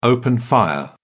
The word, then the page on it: open fire: British English